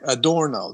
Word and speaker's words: Adorno: Or, uh, oh, sorry, Adorno. That's correct correct pronunciation.